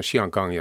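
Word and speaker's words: Xianggang: mutta Hong Kong on saman, samat kirjoitusmerkit ääntyy Hong Kong kantoniksi. Mm-hmm. Mutta, mutta mandariiniksi on Xiangang ja tuoksuva satama, kyllä kaunis nimi.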